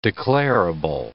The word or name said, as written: declarable